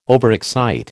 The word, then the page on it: overexcite: American English